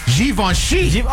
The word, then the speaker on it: Givenchy: I guess you're supposed to pronounce it with a Z, according to this. It's Z-H-E-E-V-O-N-S-H-E-E. Givenchy. Oh, you have Givenchy?